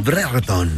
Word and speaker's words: Brereton: En la selección chilena, justamente este futbolista de apellido casi impronunciable, Brereton, ¿no? Brereton.